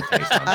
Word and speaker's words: Taysom: Well, Taysom had a touchdown in the first half. Tyson. I don't know why I'm calling Taysom.